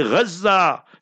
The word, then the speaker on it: Gaza: We must be very careful how we pronounce the words. Don't say Gaza, Gaza. Say Gaza. Fiha al-Izza, Gaza fiha al-Baraka, Gaza fiha al-Nusra. So the word is Gaza.